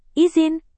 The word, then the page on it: izin: indonesian